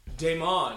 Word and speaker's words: Damon: How do you pronounce this guy's name? – Damon. – Oh, Damon.